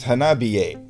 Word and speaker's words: Hanabie: The band's name is Hanabi with an E at the end. Which in Japanese would be pronounced Hanabie.